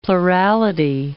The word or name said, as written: plurality